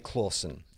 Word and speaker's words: Clauson: "You are Charlie Clawson," and he's misspelt my name. Phonetically correct. It's phonetically correct. So how do you think the Charlie bit is correct? If you were phonetically to spell Clawson, how do you think you might spell it? – C-L-A-W-S-O-N. – Close. C-L-A-U-S-O-N. He just missed one letter.